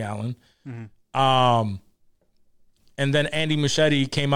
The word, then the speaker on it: Muschietti: Muschietti? Muschietti, yeah.